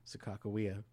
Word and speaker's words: Sakakawea: Sakakawea.